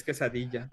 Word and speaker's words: quesadilla: You're doing it wrong, by the way. It's quesadilla.